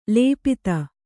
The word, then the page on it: ಲುಪ್ತ: lupta